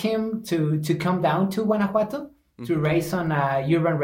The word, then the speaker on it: Guanajuato: It is Guanajuato. – Guanajuato.